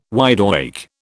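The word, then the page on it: widely: British English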